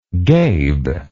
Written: gabe

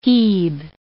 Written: hebe

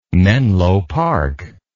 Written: menlo park

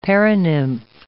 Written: paranymph

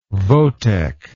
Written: votech

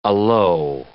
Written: alow

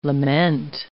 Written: lament

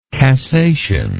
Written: cassation

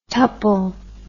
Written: tuple

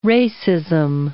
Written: racism

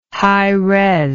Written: hires

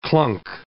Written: clunk

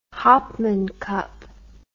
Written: Hopman Cup

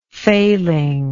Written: failingness

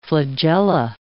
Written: flagella

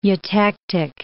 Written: eutectic

